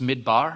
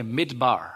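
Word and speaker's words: midbar (מִדְבָּר): So the word, ancient Jewish word for wilderness, it looks kind of like this, and it's pronounced midbar. Everyone say midbar.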